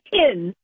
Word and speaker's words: Hinz: It's not H-I-N-S-Z. It's just H-I-N-Z, like pronounced like pins and needles.